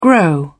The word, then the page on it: grow up: British English